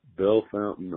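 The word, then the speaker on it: Bellefontaine: And then it's pronounced Brew Fountain, and it's Bell Fountain, Ohio. And that's how they say it.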